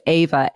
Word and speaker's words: AIVA: I was reading a paper about I'd pronounce it AIVA, A-I-V-A, which is it was set up in 2016.